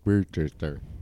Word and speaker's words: Worcestershire: Yeah, it's pronounced Worcestershire.